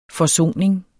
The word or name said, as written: forsoning